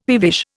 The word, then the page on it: peevish: Indian